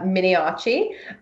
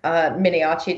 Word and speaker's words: Miniaci: I am also on Twitter, where my name is just my name, which is Sarah Miniaci. Miniaci does not have an H, despite the pronunciation. It's Miniaci.